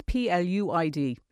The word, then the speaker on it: Pluid: And just for anybody like me, it's Pluid. It sounds like pledge, but it's spelled like Pluid.